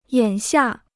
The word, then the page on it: 眼下: yǎn xià